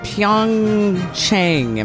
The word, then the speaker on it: Pyeongchang: It's the 2018 Winter Games in Pyeongchang. Am I saying that right? – Yes, Winter Games.